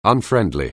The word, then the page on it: unfriendly: anglais